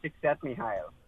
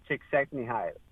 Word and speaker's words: Csikszentmihalyi: Tzitzimahali. – Can you spell it? – Yeah. No. It's that's even harder. – Mihai Csikszentmihalyi. – Say that one more time. – Mihai Csikszentmihalyi.